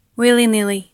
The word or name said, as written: willy-nilly